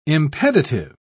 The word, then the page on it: impeditive: British English